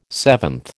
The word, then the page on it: seventh: American English